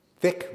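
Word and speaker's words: Th!ck: Then Mark, who was known to Priscilla at this point only by his Rec Room handle, which you might want to pronounce thick, but it's actually T-H exclamation point C-K exclamation point. That was his avatar's name. Thick returned.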